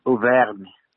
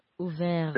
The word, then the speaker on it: Auvergne: The little town's name, and the area it's in, Auvergne. – Auvergne.